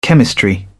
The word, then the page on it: chemistry: British English